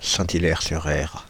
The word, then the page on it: Saint-Hilaire-sur-Erre: Paris